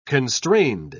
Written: constrained

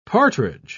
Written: partridge

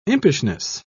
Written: impishness

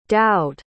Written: dowd

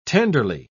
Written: tenderly

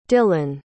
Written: dillon

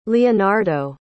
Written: leonardo

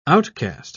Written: outcast

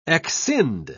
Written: exscind

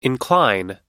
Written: incline

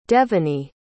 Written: devony

